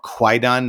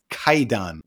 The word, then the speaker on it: Kwaidan: And actually, it's written with a W because that is echoing the pronunciation of Japanese has kind of shifted from times of old. Back then it was more Kwaidan. Now we know these things as Kaidan, which is a scary story, a horror story, a spook story.